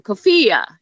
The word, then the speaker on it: Cafia: My last name is a little tricky. If we were on camera, I would have you gesture with your hand, Cafia. You can sort of get the emphasis on the fee, and you sort of wave your hand in the air like, that sort of helps people remember, but the emphasis is on the fee in FIA.